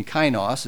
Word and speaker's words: kainos: that makes us become kainos, the other new, kainos, it's pronounced K-A-I-N-O-S, the qualitative new.